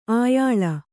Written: ಆಯಾಲ

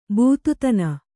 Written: ಬೂತುತನ

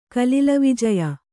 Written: ಕಲಿಲವಿಜಯ

